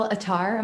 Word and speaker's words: Attar: I have Phil Attar.